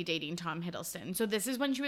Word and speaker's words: Hiddleston: Then they broke up, and then she started dating Tom Hiddleston, and then she broke up with Tom Hiddleston.